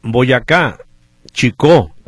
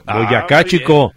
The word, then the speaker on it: Boyacá Chicó: Oye, Oscar Delgado dice Boyacá Chicó, así se pronuncia, Boyacá Chicó.